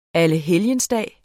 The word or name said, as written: allehelgensdag